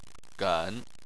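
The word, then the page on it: gen: tone 3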